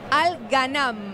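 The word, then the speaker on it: Al Ghannam: Perdóname, ¿es Al Ghanam o Al Ghanar? – No, Al Ghanam. – Ah, con N.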